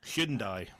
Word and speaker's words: Hyundai: And that is Hyundai. Or hang on, how do you say it in American? We might have American listeners. – Hyundai.